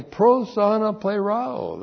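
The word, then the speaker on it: Pros, ana, plerao (προσαναπληρόω): Is not only pros ana plerao, that's what. P-R-O-S-A-N-A-P-L, long E-R-O, long O. Pros, ana, plerao.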